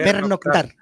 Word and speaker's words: pernoctar: ¿Dónde vas a Pecnor? A Pecnor. ¿Cómo se dice? Pernoctar.